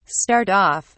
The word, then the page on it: start-off: British English